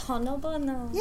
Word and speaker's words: honobono: It's Honobono. – Yay! – Am I emphasizing, am I putting the correct emphasis? Is it Honobono or Honobono? – I always just pronounce it Honobono.